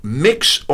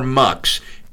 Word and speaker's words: Mx: One of the changes that I notice, the UK has accepted this, by the way, United Kingdom, the use of the term, it's pronounced mix or mux, and it's printed MX.